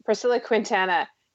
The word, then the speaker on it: Priscilla Quintana: And it was Priscilla Quintano. Whoops, I've just lost the pronunciation of her name, that had me hypnotized. I thought I was like being possessed. She was just like, Priscilla Quintana.